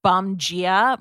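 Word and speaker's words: Bom Dia: I recently tried the new Sol de Janeiro Bomgia Bright Cream, and it's spelled D-I-A, but Bomgia, I guess, means good morning in Portuguese.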